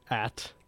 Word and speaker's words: At: It was like the At sign, pronounced At.